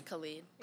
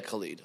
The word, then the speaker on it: Khalid: Khalid. Is that how you say it? Khalid? – I think it's Khalid. – Yeah. Yeah, Khalid. Okay, that sounds that? – Right.